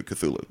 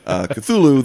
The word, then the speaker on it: Cthulhu: And I believe that there's another sort of firsthand or sort of secondhand hadith of him just pronouncing it like normal people do, Cthulhu. So okay. That's your Protestant chain back to first practices, which is Cthulhu, the way that we and Chaosium and everyone else says it.